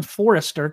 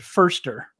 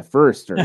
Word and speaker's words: Forster: But after what Chris Forster said, I always want to call him Forrester because that's what it looks like. And I know somebody with the last name Forrester, but he does pronounce it Forster, right? – Yeah, Forster, yeah.